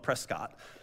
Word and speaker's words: Prescott: And no, I'm not mispronouncing it. If you're the Arizonans call it Prescott. Anywhere else in the country, they call it Prescott, but it's Prescott.